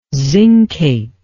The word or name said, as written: zincate